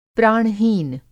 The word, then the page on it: प्राण-हीन: Hindi